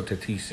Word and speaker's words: Tatis: Tatis, is that mm-hmm. how you say it? Tatis Jr. Fernando Tatis. Yeah.